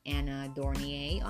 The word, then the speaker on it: Anna Dornier: either The Sugar Rebel on Facebook or Anna Dornier on Facebook.